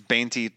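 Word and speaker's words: veinte: is is is Bainty 20 in Spanish? It is. Yeah. It's it's spelled with a V, Bainty, but a lot of times it just sounds like Bainty.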